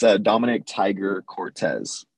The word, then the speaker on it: Dominic Tiger-Cortes: It's Dominic Tiger-Cortes.